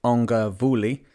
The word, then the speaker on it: ugavule: Una had caught me an ugavule, pronounced ongavuli, a giant coconut crab.